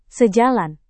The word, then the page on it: sejalan: Indonesian